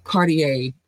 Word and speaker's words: Cartier: Cartier, the R is pronounced silently.